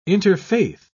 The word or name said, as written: interfaith